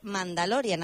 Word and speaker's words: Mandalorian: Así que vamos a empezar por lo primero de todo, porque has ido al cine a ver la nueva de Mandalorian, a ver si lo digo bien. – Sí, no, os lo han dicho bien,